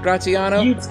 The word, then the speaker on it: Graziano: Did I say that right, Graziano? – You did. So my family's from East Boston, so we're not bougie. Like, it should be Graziano, but we kept the Boston in it.